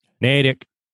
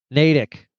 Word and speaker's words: Natick: How do you pronounce where he's from? – Natick. – Natick.